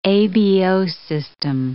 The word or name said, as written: ABO system